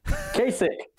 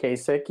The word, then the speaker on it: Kasich: Devontae Kakak? Is that how you say his last name? I probably butchered that. – Kasich. – Kasich. Oh, my God. – Kakak. – Is it really Kasich? Is it really? It's really. – It's Devontae Kasich, yeah. – Oh my God. I mean, the listeners of this podcast know I butcher names. That's kind of my thing. But how did I go this long thinking his last name was Kakak and it's Kasich? – I mean, that's how it's spelled.